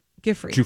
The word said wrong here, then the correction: Giuffre: Giuffre. Yeah, I watched a bunch of interviews over the weekend, and I'm assuming that's how she pronounces it, because every interview I listened to, they were like, Giuffre.